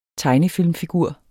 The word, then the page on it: tegnefilmfigur: [ ˈtɑjnəˌfilˀmfiˌguɐ̯ˀ ]